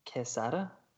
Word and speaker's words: Quesada: Quesada? – That sounds good. Like Assad, dude.